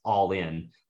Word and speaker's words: Ollin: So it's spelled Ollin, like almost sounds like Olin, but it's actually pronounced all in, like Allin.